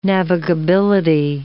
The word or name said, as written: navigability